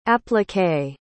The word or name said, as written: appliqué